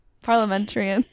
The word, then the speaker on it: parliamentarian: Schumer has already submitted a Democrat drafted Medicare prescription drug pricing proposal to the Senate parliamentarian for review.